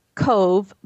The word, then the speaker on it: Cobh: It's Cove, but it's spelled C O B H.